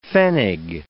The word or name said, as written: pfennig